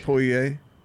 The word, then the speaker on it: Poirier: Poirier.